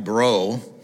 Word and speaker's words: Breau: This morning, I want to give credit to Mike Breau. And if you're from Louisiana, you know Breau is spelled B R E A U.